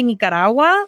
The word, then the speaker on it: Nicaragua: And after a while, to be honest with you, like, people would say Nicaragua, but they would say it instead of pronouncing it how you're supposed to pronounce it, which is how it's spelled, N-I-C, they would say it N-I-G, almost like you're saying Nigeria,